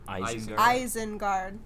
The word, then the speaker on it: Isengard: Islingard? How do you say that? Is that what it's called? – Isengard.